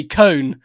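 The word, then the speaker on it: Colne: So it's really difficult because I'm a southerner, and so it's a silent L. It's spelt Coln, but people don't pronounce the L. So as a southerner, I have to say cone, but that just makes me sound really posh.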